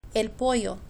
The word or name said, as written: el pollo